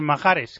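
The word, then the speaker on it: Mahrez: La primera, bueno, en realidad la primera es sobre Mahárez, que dice Sisto Miguel Serrano que se dice así, y yo le tengo que hacer caso. Mahárez,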